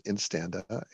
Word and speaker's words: Instanda: What is Instanda? I don't know, even know if I'm pronouncing that correctly. – You are pronouncing it correctly. It is Instanda.